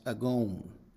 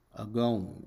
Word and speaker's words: agon: And in the Greek, the word is pronounced agon, agon, and it means a struggle or a battle or a trial.